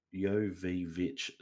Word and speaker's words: Jovovich: Is it Jovovich? – No, it's Jovovich. So, okay.